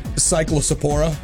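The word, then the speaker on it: Cyclospora: And cyclosporia. Am I saying it right? – Sure.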